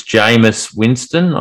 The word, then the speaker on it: Jameis Winston: Has Sean Payton been able to reduce the interceptions from, I think it's Jameis Winston? I don't think that's a typo, is it? – No, you've pronounced that perfectly.